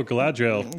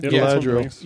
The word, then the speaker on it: Galadriel: Oh, Galadriel. – Galadriel.